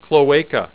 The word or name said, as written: cloaca